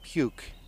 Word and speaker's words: Puke: And then I think we stopped in Puk. – We stopped in a town called Puke, P-U-K-E, really, that's how it's spelled.